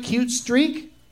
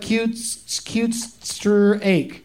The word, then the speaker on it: Cutestreak: Cute Streak? – Yep. – Okay. Because it kind of looks like cute stir ache. Straight. I want to say steak for some reason when I see it.